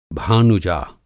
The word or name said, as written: भानुजा